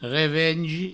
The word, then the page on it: revengi: Provençal